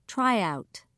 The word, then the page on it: tryout: British English